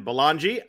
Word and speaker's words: Belongia: Joined as always on Friday mornings by Ryan Belongi.